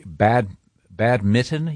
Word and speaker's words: badminton: Badminton. You don't pronounce that N.